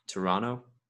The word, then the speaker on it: Toronto: And I got scolded because you're not supposed to pronounce the second T, apparently. – Toronto? Is that how you're supposed Toronto. – To say? – Toronto. Yeah, Toronto, not Toronto, which I've always been saying.